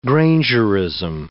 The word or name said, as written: grangerism